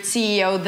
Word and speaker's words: CEO: Under 5% of CEOs, ta-da-ta-ta-ta-ta-ta-ta. Notice that in the word CEO, the stress falls on the last letter, CEO, CEO.